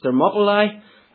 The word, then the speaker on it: Thermopylae: He famously invaded Greece, and at the Battle of, I think it's pronounced Thermopylae, 300 Spartan soldiers held a narrow mountain pass against his vast, vast army.